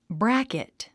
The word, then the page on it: bracket: brǽkit; ブラケト